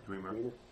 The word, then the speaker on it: Dwemer: how do you pronounce it? Dwemer? – Dwemer.